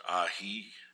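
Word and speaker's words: Ai: I believe you pronounce the leader of the Amorites, Ahi, was his name. It's just spelled A-I.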